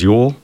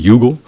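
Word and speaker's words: Youghal: Ojo is also known as Yule, or people mispronounce it Yugle, town in southern Ireland.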